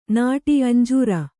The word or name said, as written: ಅಂಜೂರ